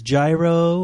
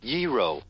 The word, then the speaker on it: gyro: look into whether it's gyro or how is it pronounced.